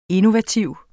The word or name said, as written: INNOVATIVE